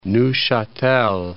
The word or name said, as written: Neuchatel